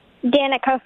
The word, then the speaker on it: Danica: Danica from Ruby Lake. – All right, Danica. Thank you. And do you spell that D-A-N-I-K-A? – D-A-N-I-C-A.